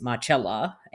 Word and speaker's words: Marcella: my real name is Marcella, and it's spelt the same as Marcella because you got the Italian pronunciation.